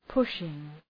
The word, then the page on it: pushing: ‘pʋʃıŋ